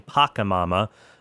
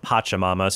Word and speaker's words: Pachamama: So on the previous episode, I was saying Pacamama, but it turns out it's pronounced Pachamama.